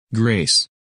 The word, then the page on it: Grace: English